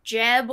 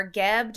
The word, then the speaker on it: Geb: So the members of the Ennead are Horus, Osiris, Tefnut, Shu, Atum, Isis, Set, Jeb or Geb, depending on if you pronounce it like Jif or Jif, uh, Gif or Jif, and then Nut.